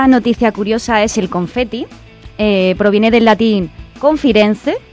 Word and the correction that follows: conficere: ¿no? ¿Se dice así? – Conficere. – Conficere.